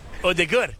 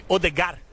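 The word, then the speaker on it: Ødegaard: What about Odegaard? – Odegaard. – Yeah. In Brazil, we say Odegar.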